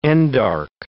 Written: endarch

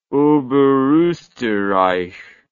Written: oberosterreich

